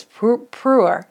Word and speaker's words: Pu'er: I am sipping on, and I say this wrong every single time, I call it Perua tea. I know it's not Perua, I think it's Pu'er tea. It's got a funky spelling, but here's what's cool about it.